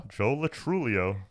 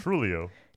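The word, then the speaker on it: Joe Lo Truglio: Joe Latrulio. – Joe Latru... There's a G. – Yeah, it's silent. – Oh, okay. Joe Latrulio. – Trulio.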